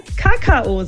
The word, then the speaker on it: Kakao: Also, ich würde ja sagen, die vorherrschende Aussprache im deutschsprachigen Raum ist Kakao. Mhm. Man kann es sich vielleicht so merken, dass ich ans Kauen denke, ja, Kakao, und dann eben auf die zweite Silbe betone. Aber, und jetzt kommt eben das Schöne, es darf anscheinend auch jeder Kakao sagen. Und hier ist jetzt die Betonung auf der ersten Silbe.